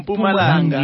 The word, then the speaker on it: Mpumalanga: What's the right pronunciation? – Mpumalanga. – Mpumalanga. – There you go.